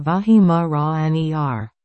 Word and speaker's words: Weimaraner: Weimaraner, pronounced Vahimaraner.